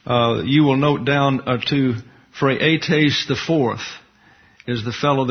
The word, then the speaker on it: Phraates: Phraates is the way it's pronounced in the Greek.